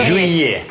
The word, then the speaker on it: juillet: Жюе.